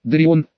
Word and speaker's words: Дарион: «Итак, Дарион», — она произнесла мое имя с легким акцентом, делая ударение на последнем слоге, — «что привело тебя в мое скромное заведение?» «Астролит, я не видел смысла ходить вокруг да около.